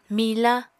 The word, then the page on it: mila: Apatani